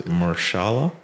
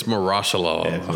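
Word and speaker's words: Mahershala: Marshala? – It is not Marshala. – God damn it. – It's Marashala.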